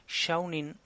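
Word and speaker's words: Seanan: Eh, la, siguiente categoría es, Mejor Novela de Fantasía, que aquí gana Middle Game de Shannon McQuire. Bueno, en realidad es Shaunin McQuire, porque la Wikipedia te dice cómo tienes que pronunciarlo, pero yo no, no sé.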